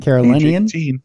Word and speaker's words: Carolinian: Following his arrest early last week, the South Carolinian, is that how you say it? South Carolinian. – Carolinian.